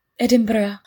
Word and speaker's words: Edinburgh: It's not like Edinburgh. Did I say it right? – You did.